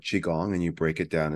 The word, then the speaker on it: Qigong: Please tell us for the people who don't know, what is Qigong? – Qigong very well, very good pronunciation.